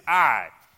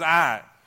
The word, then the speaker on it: Ai: That really is Ai. I know the spelling is, but the right pronunciation is Ai.